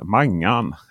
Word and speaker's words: mangan: Eh, just den delen är ju, är ju magan. Heter det? Mangan.